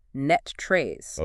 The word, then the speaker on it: NTREIS: is pronounced locally as NETTRES.